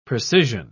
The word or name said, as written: precisian